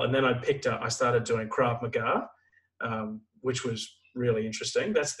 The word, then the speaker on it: Krav Maga: It was called Krav Maga, K-R-A-V. Yeah, Krav Maga, Krav Maga.